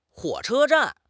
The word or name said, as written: huo3 che1 zhan4